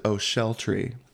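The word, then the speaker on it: Ochiltree: There's actually an Okletree village in Scotland, but they pronounce it O'Sheltree.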